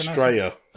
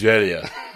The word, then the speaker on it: Estrella: So, like, this is Estrella. Is that so? How do you say it properly? – Estrella. – Estrella. – Yeah. Like Mallorca. Double L is a Y in Spanish.